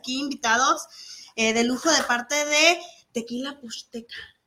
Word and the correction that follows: Pochteca: Y si lo dije bien, ¿verdad? Pochteca. Pochteca.